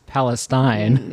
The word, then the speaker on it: Palestine: So correctly pronounced is Palestine.